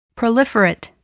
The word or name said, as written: proliferate